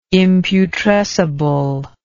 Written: imputrescible

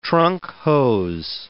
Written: trunk hose

